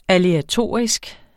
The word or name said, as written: aleatoriske